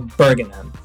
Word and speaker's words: Bergenhem: Carl Bergenhem.